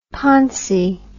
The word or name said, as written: poncy